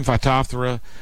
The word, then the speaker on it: Phytophthora: Pythium, Phytophthora, bacterial wilt, which is, I forgot what bacteria it is.